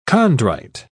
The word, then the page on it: chondrite: British English